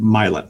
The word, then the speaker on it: Milan: There's a city next to us called Milan, except we pronounced it Milan.